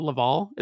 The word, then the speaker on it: Laval: And it's called Laval, is that or am I saying that correctly? – Yeah it's a small town.